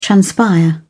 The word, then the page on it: transpire: British English